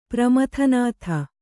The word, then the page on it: ಪ್ರಮಥನಾಥ: Kannada